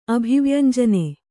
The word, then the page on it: ಅಭಿವ್ಯಂಜನೆ: abhivyañjane